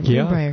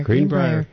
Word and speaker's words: Greenbrier: Am I pronouncing that? Yeah, Greenbrier. – Yeah, Greenbrier.